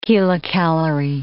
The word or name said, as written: kilocalorie